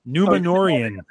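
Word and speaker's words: Numenorean: Numerian, their Adore album. Numenorian. See, I told you I will butcher it.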